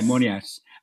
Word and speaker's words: Moonias: Rightfully, it's usually spelled M-O-O-N-I-A-S. – Yes. – Yeah, Monias. As in, it rhymes with Sunia, which means a little bit of money.